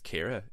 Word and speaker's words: Kira: I'm glad that Justin referred to her as Sierra 23 times in the song, because her name is Kira. It's spelled Kira. That's exactly how you spell Kira.